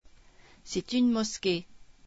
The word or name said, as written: mosquée